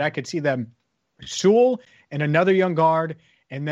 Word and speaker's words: Sewell: But I do think that the strategy of, okay, we're going to draft Penei Sewell anyway, or Sewell, God, I keep saying it wrong, Penei Sewell anyway,